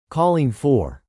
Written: calling for

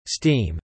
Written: steam

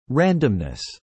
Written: randomness

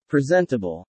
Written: presentable

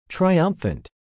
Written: triumphant

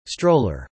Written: stroller

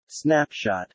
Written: snapshot

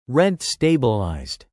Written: rent-stabilized

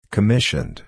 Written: commissioned

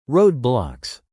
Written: roadblocks

